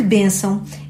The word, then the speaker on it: bênção: Contudo, em alguns casos, pode não coincidir com o acento tónico, de que bênção é exemplo.